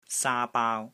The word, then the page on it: SAA1 BAAU1: Cantonese